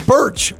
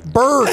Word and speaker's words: Burch: And I said, no, you can't. You know, it's Birch. Birch. – I said, Birch.